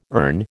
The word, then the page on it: erne: American English